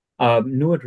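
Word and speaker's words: NEID: NUID rhymes with fluid, basically.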